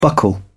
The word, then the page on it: buckle down: British English